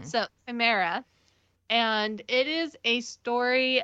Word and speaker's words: Chimera: How do you say that? – Chimera. – Chimera. – Yeah, and I don't know that they're pronouncing it quite like that. – It's not how it's spelled. It's said different. Chimera. Yeah. That's how I've heard it.